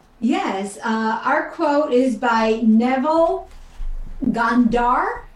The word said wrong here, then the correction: Goddard: Am I pronouncing that correctly? – Neville Goddard. – Goddard. Sorry, Neville.